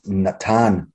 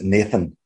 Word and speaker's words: Natan: And I look at that and I look at the word give, and it says it's Natan. And for me, I would pronounce it Nathan, but it's not that.